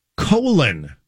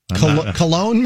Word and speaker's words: Colon: Colon, Michigan, by the way. – Cologne, maybe? – It's even phonetically spelled out. It is colon.